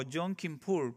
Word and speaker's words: Yom Kippur: Yom Kippur, como lo conocen, no sé si lo estoy pronunciando bien, así es.